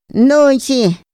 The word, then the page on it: nonxi: Otomí